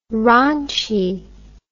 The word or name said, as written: ronchie